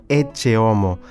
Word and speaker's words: Ecce homo: que em latim se pronuncia «Ecce homo», daí o nome dado à imagem que é transportada solenemente neste préstito.